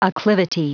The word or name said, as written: acclivity